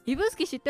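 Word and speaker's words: 指宿: あ、イブスキ知ってると、イブスキ聞いたことある、イブスキ知ってます。イブスキってこうやって書くんですね。どうやってイブスキって読むのこれ、指に宿でイブスキって読むんだって。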